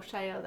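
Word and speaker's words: Chaillot: Sadly, Phoenix Theater's Mad Woman of Shalott, is that how you say it? – Shio, that's right.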